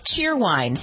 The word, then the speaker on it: Cheerwine: It's called Cheer Wine. – Cheer. – Cheerwine. It's a soda. – Cheerwine. – But the I is silent. It's just cheer wine.